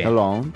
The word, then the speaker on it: Lens: Long. – Porque la S al final tampoco se debería pronunciar.